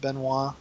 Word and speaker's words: Benoit: I said, I don't remember who Big C is, and I don't know this Chris Benihana? What'd you call him? – Well, it's spelled like Benoit, but it's pronounced Benoit.